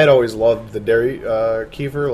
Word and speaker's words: kefir: Well, I say kefir. – It? Yeah. – Yeah, now. I used to say kefir, but Courtney told me that it was the other way, so.